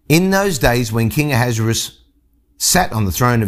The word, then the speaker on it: Ahasuerus: Now it came to pass in the days of Ahasuerus, and there are multiple ways to pronounce his name. Some people call him Ahasuerus, Ahasuerus.